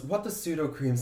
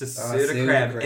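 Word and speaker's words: Sudocrem: The name changed to pseudocrim in 1950 due to the Dublin accent pronunciation of soothing cream.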